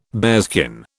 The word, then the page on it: bearskin: British English